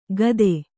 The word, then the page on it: ಕತ್ತೆ: katte